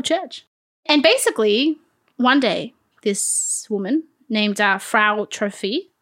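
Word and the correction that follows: Frau Troffea: Wait, you got to do my pronunciation. Frau Trophia.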